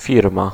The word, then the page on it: maison: /mɛ.zɔ̃/